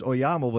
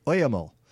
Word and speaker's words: Oyamel: I said, how do you pronounce Oyamel? And I thought, because I used to think it was Oyamel, but it's Oyamel.